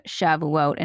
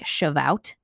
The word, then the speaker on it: Shavuot: I would say I had never even really heard of Shavuot until I started working at Jewish Voice, nor could I pronounce it even. I always thought it was like Shavout, kind of looks like that.